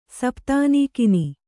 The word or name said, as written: ಸಪ್ತಾನೀಕಿನಿ